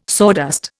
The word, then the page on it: sawdust: Indian